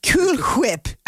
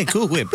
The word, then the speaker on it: Cool Whip: That's why I say cool whip. You're saying it weird. – You're saying it weird, man. You're putting the emphasis on the H. It's just silent.